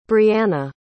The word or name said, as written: brianna